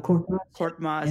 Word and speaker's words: Korkmaz: Korkmaz. Is that how you pronounce it? – Furkan Korkmaz.